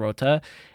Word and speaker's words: crwth: You know, this one instrument that we have here called a rota, which is, it's a Welsh instrument, so it's spelled C-R-W-T-H, but I believe is pronounced rota.